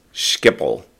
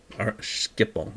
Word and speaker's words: Schiphol: But when you Google it, it does say the British or the American pronunciation is Schiphol. But who cares about how we say it? Let's say it like how the Dutch say it.